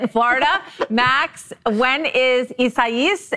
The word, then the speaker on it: Isaias: Right now, Category 3, Isaias is hammering the Gulf Coast with torrential rain and steadily increasing wind. Isaias? – That's how you're supposed to say it, right? – Yes.